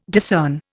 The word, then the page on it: discern: Indian